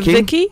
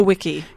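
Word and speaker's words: Wicki: Is it Vicky or Wiki? – Yes, I believe it's Wiki. Ludwig. – Sometimes Wiki. – The W's are V's, aren't they? – Yes, yeah, correct.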